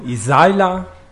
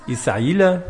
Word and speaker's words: Isaila: Also ich heiße Livio Isaila, auf Deutsch wird das so ausgesprochen, wie ich jetzt sagte. Rumänisch heißt das Isaila. Okay, kein Problem. So oder so, ist egal.